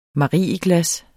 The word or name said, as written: MARIEGLAS